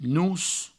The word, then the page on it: nos: nous